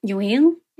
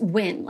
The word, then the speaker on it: Nguyen: So in Australia, like the surname Nguyen isn't pronounced Win like it is in America. It's pronounced Nguyen.